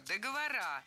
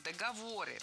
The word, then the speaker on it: договоры: Вторая ошибка – договора. Более корректный вариант – договоры. При склонении ударение в слове «договор» сохраняем на третьем слоге. Договоры, договоров, договорами.